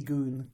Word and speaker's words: Gun: Chaos and Suzuki Goon. – Yeah. – I'm going to keep saying that, even though I feel much more comfortable saying gun. I'm forcing myself to use the Japanese pronunciation.